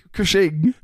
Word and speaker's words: Cushing: How do you say it? – Cushing. – Cushing.